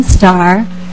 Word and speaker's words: Starr: My name is Vivian Starr, and it's spelled S-T-A-R-R.